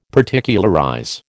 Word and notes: Particularize: American English

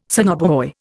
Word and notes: sonobuoy: Indian

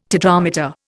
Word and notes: tetrameter: Indian

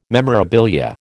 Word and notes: memorabilia: American English

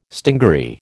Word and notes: stingaree: American English

